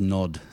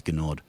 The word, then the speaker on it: Gnod: That's Nod, spelt with a silent G at the beginning. I think it must be silent, otherwise it's Gnod.